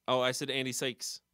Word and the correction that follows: Serkis: Sykes? – Andy Serkis. – Serkis.